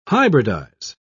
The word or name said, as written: hybridize